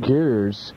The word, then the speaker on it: Gers: Iron Maiden has already found a replacement for recently departed guitarist Adrian Smith, one Janet Gers, forming G-E-R-S, I don't know how to pronounce it. – Gers.